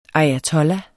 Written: ayatollah

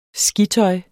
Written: skitøj

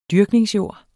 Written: JORDSMONNET

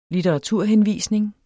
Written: litteraturhenvisning

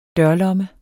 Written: dørlomme

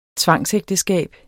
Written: tvangsægteskab

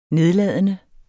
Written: nedladende

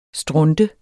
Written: strunte

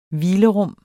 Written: hvilerum

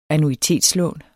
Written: annuitetslån

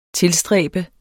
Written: tilstræbe

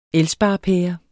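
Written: ELSPAREPÆRER

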